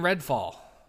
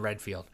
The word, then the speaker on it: Redfall: Starfield and Redfall. I said it right. I always want to say Starfall and Redfield.